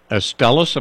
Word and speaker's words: Astellas: And this comes courtesy of Astellas. Am I pronouncing that correctly? – You are.